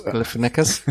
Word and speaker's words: Galifianakis: Und der Joker wird in den USA von Zack, äh, jetzt hoffe ich, kann ich den Namen richtig aussprechen, Zack Galifianakis. – Galifianakis.